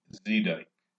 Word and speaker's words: Zedyk: Marvin E. Zedyk, and that's pronounced Z-E-E-D-Y-K.